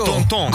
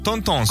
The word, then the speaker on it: tonton: Come si dice in francese, zio? – Zio, lo zio. – Tonton. – Con l'accento sulla O. – Tonton, com'è? Si dice. Tonton.